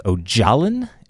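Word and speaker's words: Öcalan: His name is Ocalan, but it's not spelled like that. It's like O-C-A-L-A. And the C is always pronounced as a J in Kurdish.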